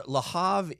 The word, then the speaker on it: Le Havre: Le Havre is a. – Oh no! I there's no. You don't pronounce the R.